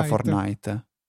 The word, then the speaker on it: Fortnite: Quindi Uh, scritto Fortnite, onestamente mi trovo un po' in difficoltà a pre- pronunciarlo. Fortnite. Mm, penso sia Fortnite. Sì, sì.